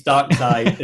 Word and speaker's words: Darkseid: It's Darkseid, as in Dark Side of the Force. Like just think Dark Side.